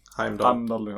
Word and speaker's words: Heimdall: Hamidal. Mm. Och... – Heimdall. – Ja. Jag är så dålig på uttal.